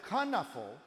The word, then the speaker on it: Knuffle: No, actually, I pronounce it Knuffle because Knuffle is a Dutch word.